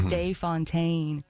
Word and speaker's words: De Fontaine: I think we just like the sound of it, but we like the American pronunciation of it almost too. Like it was Day Fontaine.